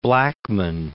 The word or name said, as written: Blackmun